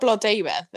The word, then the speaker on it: Blodeuwedd: And from them, they conjure up Blodwedd to be his wife. – So that's not Blodeuwedd, though, is it? – Blodeuwedd. Yeah, sorry, I'm saying it wrong.